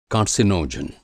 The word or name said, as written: कार्सिनोजन